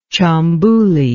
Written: Tchambuli

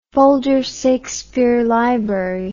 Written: Folger Shakespeare Library

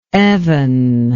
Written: evan

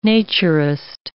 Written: naturist

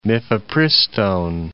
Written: mifepristone

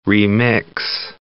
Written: remix

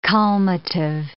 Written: calmative

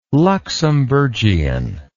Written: luxembourgian

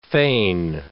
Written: fane

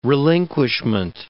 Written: relinquishment

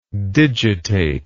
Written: digitately

